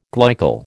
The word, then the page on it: glycol: American English